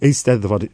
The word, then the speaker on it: Eisteddfod: Eisteddfod, it's literally a chairing.